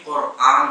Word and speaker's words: قرآن: اس کا صحیح تلفظ بھی قرآن ہوگا